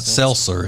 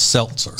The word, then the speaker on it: seltzer: So seltzer really does have the T in it, right? So you're supposed to say that. It's not seltzer, it's seltzer.